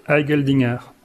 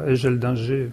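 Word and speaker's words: Eigeldinger: Alors il a un nom qui se prononce difficilement, enfin en tout cas selon les langues, donc comme je suis en Alsace, je vais le prononcer de façon plutôt alsacienne. Donc c'est le protestant suisse Marc Heigeldinger. Alors en français, ça serait, je sais pas, Heigeldinger, qui ne fait pas partie des poètes suisses les plus connus.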